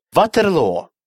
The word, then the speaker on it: Waterloo: Waterloo. Маленькая деревня под Брюсселем. Обратите внимание на то, что в песне это название произносится на английский манер.